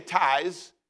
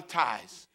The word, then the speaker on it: tithe: Don't say tithes, because it's not a tithe. So everybody say tithe. T-I-T-H-E, tithe. It means tenth.